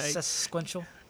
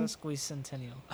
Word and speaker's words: sesquicentennial: Sesquential. – Sesquicentennial? – Sesquicentennial.